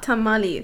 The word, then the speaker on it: tamal: Here I was thinking it was tamale, my dumbass.